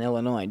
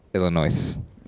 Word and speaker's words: Illinois: There's no way you really say the S in Illinois, do you? – Illinois.